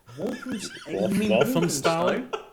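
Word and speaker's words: Walthamstow: Waltham Stow? – Yeah, there we go.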